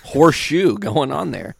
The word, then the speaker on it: Horshu: And his name was Horseshoe. – Really? Horseshoe? That's awesome. – But it was spelled like H-O-R-S-H-U. – Horseshit? – No. H-O-R-S-H-U. – U. – Horseshoe.